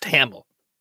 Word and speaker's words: Hamill: Mark Handel? – It's pronounced Hamill. – Well, I'm reading it Handel.